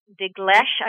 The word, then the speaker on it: Dinklage: I believe that the Peter Dinklage, I'm not sure if I'm pronouncing his last name correctly. – Yep.